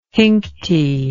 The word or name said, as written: hinkty